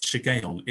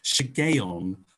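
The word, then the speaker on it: Shiggaion: If I pronounce it right, hooray, 10 points for me, but I might not. I think it's pronounced Shigeon. It is called a Shigeon of David.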